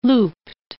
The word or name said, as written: looped